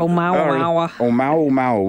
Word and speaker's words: Oumuamua: Help me pronounce this Hawaiian asteroid. I say Hawaiian, it's not Hawaiian, but it was... – The Mauomao. I can't even pronounce it. – Oumuamua. We'll go with your pronunciation.